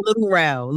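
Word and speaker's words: Lil Rel: Am I saying his name right? I am. Lil Ray. He's the comedian, right? He actually, Lil Ray plays Santa Claus. – Sorry, it's Little Rel.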